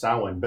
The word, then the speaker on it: Samhain: And of course, this triggers my whole thing of it's pronounced Samhain, but that's just me.